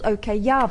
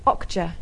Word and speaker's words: Okja: Yeah, I thought it was called OK Yeah, but it's Okja.